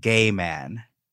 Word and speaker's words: Gheman: There's this kind of socialite lady that was around the Sunset Strip scene in L.A., and this woman's name is Pleasant Gay Man. – Get out of here. – But it's spelled G-H-E-M-A-N.